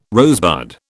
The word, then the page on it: rosebud: British English